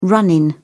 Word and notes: run in: British English

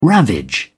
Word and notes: ravage: British English

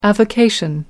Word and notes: avocation: American English

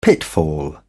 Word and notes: pitfall: British English

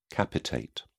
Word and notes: capitate: British English

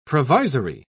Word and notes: provisory: British English

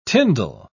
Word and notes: tindal: British English

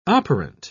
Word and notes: operant: British English

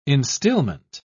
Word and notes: instilment: British English

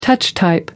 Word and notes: touch-type: American English

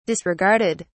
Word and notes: disregarded: British English

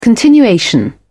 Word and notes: continuation: British English

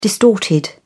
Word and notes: distorted: British English

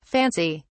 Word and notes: fancy!: British English

